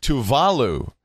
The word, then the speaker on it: Tuvalu: I've been told I can't say Tuvalu like Tuvalu anymore because it's pronounced Tuvalu.